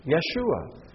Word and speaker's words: Yeshua: Joshua, the son of Nun, pronounced in Hebrew, Yehoshua. And after the Babylonian captivity, Yeshua.